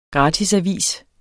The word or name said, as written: gratisavis